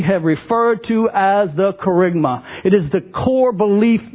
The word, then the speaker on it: kerygma: Kerygma is spelled K. E-R-Y-G-M-A, kerygma.